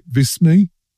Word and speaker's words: Visme: VISME? V-I-S-M-E? – Yeah, I said VISME. You said VISME. Either one works.